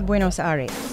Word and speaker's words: Buenos Aires: Buenos Aires.